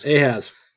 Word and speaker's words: Ahaz: Then we get Ahaz, how do you say that? – Ahaz.